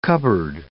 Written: cupboard

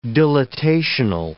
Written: dilatational

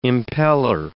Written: impeller

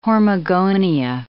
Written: hormogonia

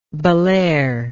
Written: bellaire

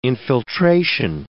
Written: infiltration

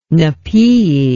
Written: napaeae

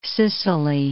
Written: Sicily

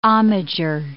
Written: homager

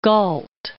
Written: Galt

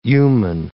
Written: Yuman

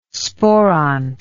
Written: sporont

